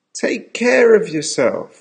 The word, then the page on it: take care of yourself: American English